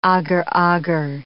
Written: agar-agar

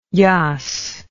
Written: jass

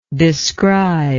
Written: describer

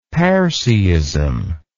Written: parseeism

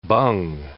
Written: bung

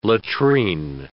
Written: latrine

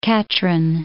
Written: Katrine, Loch

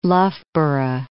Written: Loughborough

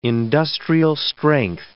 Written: industrial-strength

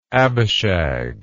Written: Abishag

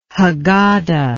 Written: haggada

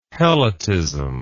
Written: helotism

